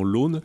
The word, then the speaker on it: Lompnes: Ça s'écrit Lompnes, mais ils prononcent localement L'Aune.